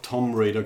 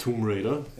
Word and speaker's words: Tomb Raider: Ja, und dann haben wir halt alle brav Tom Raider gesagt, gell? Statt Tomb Raider.